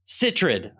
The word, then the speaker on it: Sitterud: Sitterud.